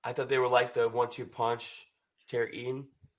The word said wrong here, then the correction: Etienne: Uh, how you pronounce his name? – Etienne. Etienne. – Etienne. Okay, my bad.